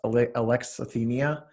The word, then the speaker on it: alexithymia: So again, that's alexithymia. It's spelled like Alex, I-T-H-Y-M-I-A, if you're looking to search it.